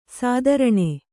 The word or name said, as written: ಸ್ತ್ರೈಣ